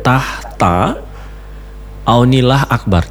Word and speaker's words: Tahta Aunilah Akbar: Tahta Aunilah Akbar. Gimana sih bacanya? – Iya, Kak, sudah cocok.